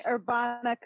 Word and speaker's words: Urbana: Yes, you are pronouncing Urbana correctly. And Urbana, I'm going to segue for a second. That's all it is in Italian. It's Herb. It's Urbana.